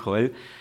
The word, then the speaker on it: Joel: Y la verdad es que mi nombre, el que me pusieron mis padres es Joel, pero un montón de gente me llama Joel. Si me llamas Joel no, pasa nada, es simplemente una cuestión de pronunciación.